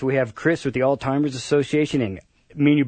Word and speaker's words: Alzheimer's: I like to say, remember all's fair. – All's so, fair. Alzheimer's,